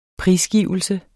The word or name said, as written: prisgivelse